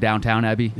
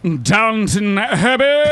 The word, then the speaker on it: Downton Abbey: Everyone calls it Downtown Abbey.